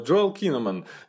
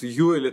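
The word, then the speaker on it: Joel: Э, и я думаю, так, стоп, он пишется-то, в принципе, да, Джоэл. Может, Юэль, это может опять русская вот эта вот адаптация. – Я думаю, что он по-шведски так звучит.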